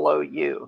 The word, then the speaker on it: MyrnaLou: MyrnaLou. It's all one word. Like Mary Lou, only it's Myrna Lou.